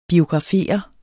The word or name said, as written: biografere